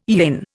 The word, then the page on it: yin: Indian